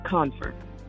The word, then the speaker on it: Confer: It's just like the verb confer. C O N F as in Frank E R, but it's pronounced con- confer.